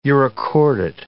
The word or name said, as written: urochordate